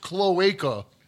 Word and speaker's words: cloaca: It's pronounced cloaca.